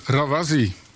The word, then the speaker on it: Rawazi: En u bent van het koor Rawazi. Zeg ik dat goed? – Dat zeg je heel goed.